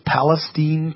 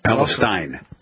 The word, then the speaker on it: Palestine: Yeah, Palestine's Steen. A traditional pronunciation. Yeah. – See, my producer told me, make sure you say Steen. – I did. I sent him an email. So remember, this is Steen.